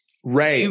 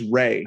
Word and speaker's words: Rhea: Ray. I think it's just Ray. – Oh, just Ray Seehorn? – Yeah, Ray Seehorn.